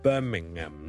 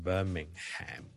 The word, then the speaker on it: Birmingham: 如果你美國人咧，可能會讀埋個 ham 出嚟，Birmingham 咧就可能會讀成 Birmingham 咁樣。咁但係如果英國咧，大部分人咧個 H M 都係唔同嘅。